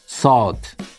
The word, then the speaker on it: salt: 소금은 다들 아시는 단어 salt. salt 발음하실 때 입을 어 모양을 하시고 아 소리 내시면 됩니다. 이렇게요. 아, 아, salt, salt.